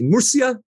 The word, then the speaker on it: Murcia: Murcia, Spain, I think that's where they're going to be playing.